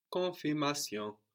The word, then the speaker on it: confirmation: So both ends here are half silent. Let's say no. Confirmation. So those ends on the N on the end and also the N on the end of that syllable, no? Con is not really pronounced.